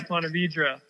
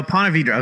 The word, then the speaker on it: Ponte Vedra: So I know you grew up in, what was it, Point Verde Beach? – Yeah, Ponte Vedra. – Oh, Ponte Vedra. Okay, I butchered that.